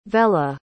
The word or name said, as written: vella